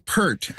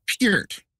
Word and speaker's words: Peart: I've been saying Neil Peart, as in Pearl. – Yeah. – It's Peart.